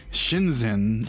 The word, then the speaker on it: Shenzhen: So apparently, Jake, you have told me there is another legal fake store that has popped up in Shenzhen, China.